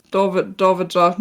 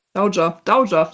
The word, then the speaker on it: Dowager: Und weil sie ja verwitwet war und damit ja der Erbtitel an seinen Bruder ging, geht ja davor dieses Dowager, nee, das habe ich auch falsch ausgesprochen. – Dowager? – Dowager, so wird es ausgesprochen.